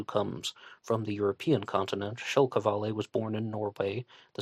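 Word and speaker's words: Kjell Qvale: Mike Rodelli believes that the Zodiac killer was a Norwegian-American named Kjell Kavale. His first name is spelled Kjell, K-J-E-L-L, and Kavale is spelled Q-V-A-L-E.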